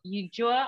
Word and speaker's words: Ulloa: And this week we have Michael Ulloa on.